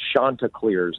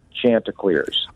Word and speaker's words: Chanticleers: It's actually pronounced Chanticleers, not Chanticleers.